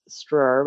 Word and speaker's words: Stroeer: But yeah, my last name is Stroeer, which is Stroeer.